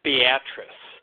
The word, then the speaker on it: Beatrice: So the town is actually pronounced Beatrice. I would have thought that it was Beatrice.